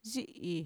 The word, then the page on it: yi'i: Mixtec